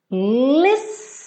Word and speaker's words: listen: Listen Listen. O som dessa palavra é como se fossem dois S's, né?